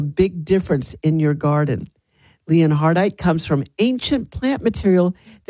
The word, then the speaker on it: Leonhardite: And the dirty word of the day is Leonhardite. Leonhardite. That's spelled L-E-O-N-H-A-R-D-I-T-E.